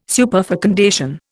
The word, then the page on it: superfecundation: Indian